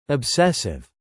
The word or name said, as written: obsessive